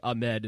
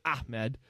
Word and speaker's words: Ahmed: I was right there with a lot of other people saying Ahmed, and apparently that's not how you spell it. Ahmed. A little emphasis on the ah.